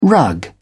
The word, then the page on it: rug: British English